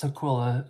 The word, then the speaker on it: Tukwila: Is it Tukwila? – It's Tukwila. Tukwila. Some people say Tukwila.